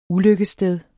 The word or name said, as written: ulykkesstedet